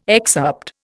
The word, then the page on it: excerpt: Indian